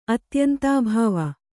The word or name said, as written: ಅತ್ಯಂತಾಭಾವ